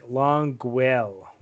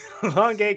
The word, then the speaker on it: Longueuil: So okay, if you can see the notes, how do you pronounce this town in Quebec? You're Canadian. It's like Longueuil. There we go. Say it again for us. There you go. Longueuil, Quebec.